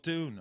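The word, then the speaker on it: DVNE: J-U-N-E. – No, it's D-V-N-E. – D-N-E. – Oh, Dune.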